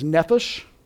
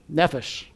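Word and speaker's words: nephesh (נפש): The Hebrew word is nephesh. Nefesh, okay? The unique Hebrew word, the accent's on the first syllable. Unlike Shema, accent on the second syllable, Hebrew, it's the accent is on the first, nefesh.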